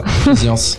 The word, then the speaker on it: Physiance: Aujourd'hui, nous recevons la société Physiance, je le dis bien ? – Oui, c'est ça, Physiance.